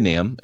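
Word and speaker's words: NAMB: And bivocationalism really makes sense when, like, for example, when I came to NAM, and by the way, for our listeners, that's North America Mission Board, the B is silent.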